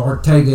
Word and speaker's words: Arcega: J.J. Arcega actually looks like he would be pronounced Arcega-Whiteside, but he's from Spain, so Arcega it is.